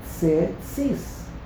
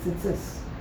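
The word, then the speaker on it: Tzitzis (ציצית): The first Tzadi, they pronounce as a Shva, Tze. The second Tzadi, they pronounce differently because there's a Yud, Tzitzis. But the truth is, most people don't even do that. They just say Tzitzis. They do a double Shva, which is incorrect.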